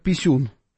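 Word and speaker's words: P1-SUN: Встречался он там с Зеленским, фотографировался с дроном украинским, дроном-перехватчиком, который называется латинскими буквами P1 дефис Sun, что читается по-русски как писюн, никак иначе. Так, ну, так и читается, да, вот так называется, вот, посмотри, вот он, этот дрон.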